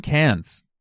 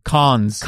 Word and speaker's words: Cannes: Cons. – Con. – How do you say it? – Con. – I say cans. – The Cannes Film Festival. Cans is what you get your soup in.